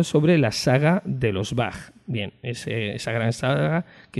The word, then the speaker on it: Bach: De hecho, ni siquiera sería incorrecto decir Bach. Yo personalmente no corregiré a nadie que diga Bach, pero lo lógico es pronunciarlo tal y como suena en el idioma original.